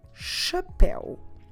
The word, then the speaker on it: chapéu: The word for hat is chapéu. Chapéu. Como se escreve chapéu? C H A P E com acento U.